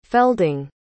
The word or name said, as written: felding